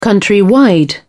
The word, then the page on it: countrywide: British English